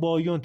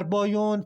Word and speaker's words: بایون: بعد از مدتی خمرهای محلی که در بازسازی این معبد کمک میکردن بانیان رو به اشتباه بایون تلفظ میکردن که به مرور زمان این اسم یعنی بایون روی این معبد ماندگار شد.